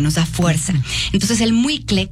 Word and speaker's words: muicle: Se llama muicle, se escribe M-U-I-C-L-E, muicle.